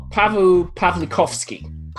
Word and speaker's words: Paweł Pawlikowski: Pavu Pavlikovsky.